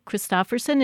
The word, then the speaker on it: Christofferson: We're talking with author Travis Christofferson.